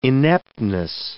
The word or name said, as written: ineptness